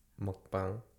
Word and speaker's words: mukbang: We were thinking about doing a mukbang. – Yeah. You said it correctly. Us Americans say mukbang. – Because mukbang. I think it's because, you know, in Korean it's mogo, mok, eat, pang. It's a room.